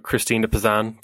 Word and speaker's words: Christine de Pizan: Christine de Pizan, yes.